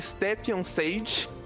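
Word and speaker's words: Stjepan Šejić: Eu acho que o nome dele pronuncia Stepien Sage, pelo que eu li numa thread no Twitter sobre como pronunciar o nome dele.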